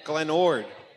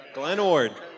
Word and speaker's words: Glen Ord: Glen Ord. – Spell it. – O-R-D. Glen Ord.